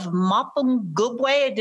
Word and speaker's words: Mapungubwe: Now, I wanted to mention that in 2008, you received the Order of Mapungubwe. Did I pronounce that right? – That's brilliant. It's amazing for an American to be able to say that. It's amazing because you got it absolutely spot on.